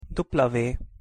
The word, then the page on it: w: Hungarian